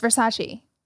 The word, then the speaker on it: Versace: Addison Rae under heat for pronouncing Versace wrong. – Oh, yeah, because it was Versace, Versace. – What did she say? – Oh, God. Versace. – And it's Versace.